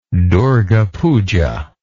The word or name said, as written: durga puja